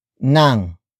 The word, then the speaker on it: ng: The next word is nang. Nang. Nang. Nang is spelled as ng.